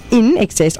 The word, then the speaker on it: INXS: They changed their name to INXS, pronounced In Excess, obviously, in 1980, on the suggestion of Midnight Oil's manager, Gary Morris.